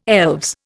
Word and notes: elves: Indian

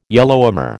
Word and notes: Yellowhammer: American English

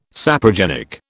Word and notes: saprogenic: British English

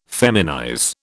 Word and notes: feminize: British English

